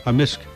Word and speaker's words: Amisk: Amisk. – Amisk. – Amisk. – So the K can be silent, but in generally it's... – Yeah, amisk. Wah, wah, amisk.